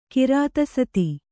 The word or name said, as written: ಕಿರಾತಸತಿ